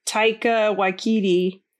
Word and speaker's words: Taika Waititi: Like, Thor had Taika Waititi.